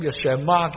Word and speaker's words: Shema: 同埋呢字经文，因为希伯来文的开头用听这个做开始，所以这个经文呢就用希伯来文的读音叫 Shema 的经文。